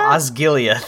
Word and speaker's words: Osgiliath: Osgiliath.